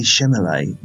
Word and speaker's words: Chalamet: I knew there was going to be some dancing involved with Timothée Chalamet. That's not his... How do you pronounce his surname? – Chalamet, there we go.